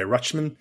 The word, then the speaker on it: Rutschman: I think you pronounce the T too. – Okay, Rutschman.